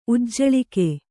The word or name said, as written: ಉಜ್ಜಳಿಕೆ